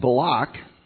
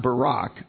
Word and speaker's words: Balak: Remember the story where Balaam was hired by Balak, or as it's pronounced, Balak, not Barak, but Balak.